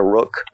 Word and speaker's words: roc: Basically, it's a giant eagle, but it's known as a rook, or it's spelled ROC. Okay.